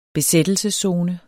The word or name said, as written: besættelseszone